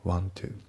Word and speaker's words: want to: では発音ですけど、まず want to の T がね、抜けますね。 want to。 want to じゃなくて want to だね。